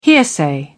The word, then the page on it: hearsay: British English